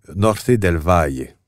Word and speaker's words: Norte del Valle: Je devrais prononcer Norte del Valle, en fait. T'as pas besoin de le prononcer comme ça.